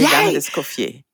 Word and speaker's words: Les Dames d'Escoffier: Is it Les Dames d'Escoffier? Is the D pronounced? – Exactly. Perfect. – Yay. Les Dames d'Escoffier.